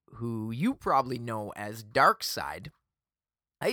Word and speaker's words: Darkseid: And they, of course, said it's pronounced Darkseid. Like the dark side of the force, for example.